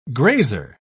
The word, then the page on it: grazer: British English